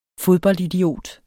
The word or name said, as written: fodboldidiot